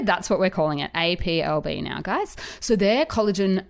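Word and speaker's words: A'pieu: it's no longer Appleby. We're going to go with the English, like, um, pronunciation. So it will be now APLB.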